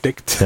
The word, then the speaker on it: DECT: Genau, auch deckt ausgesprochen von manchen Leuten. Ja, Neudeutsch deckt.